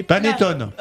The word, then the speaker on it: panettone: Les Italiens se réunissent autour des typiques cendres apportées et abondantes, comme la fameuse patanone ou encore les panettone. – Panettone. Oui. Oh, que tu prononces bien.